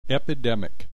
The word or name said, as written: epidemic